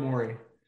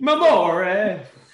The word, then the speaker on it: Morey: Daryl Morey. – Morey. Thank you. Gave him like a little Italian. I don't know what I was doing. but he Mamore.